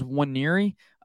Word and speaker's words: Wanyiri: Is right after the commitment of Williams Wanyiri, I believe that's how you pronounce it. I put a pronunciation thing in here on the show notes, and I think it's Wanyiri. The N is silent in his name.